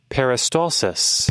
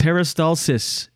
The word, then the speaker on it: peristalsis: I don't know how to pronounce it in English. Oh, let's see. – Peristalsis. – Peristalsis. In French, peristaltisme. – Peristalsis.